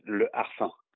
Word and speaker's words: Le Harfang: Am I pronouncing that correctly? – Well, in French, we're going to pronounce it Le Harfang.